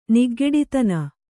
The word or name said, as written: ನಿಗ್ಗೆಡಿತನ